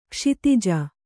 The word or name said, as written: ಕ್ಷತಜ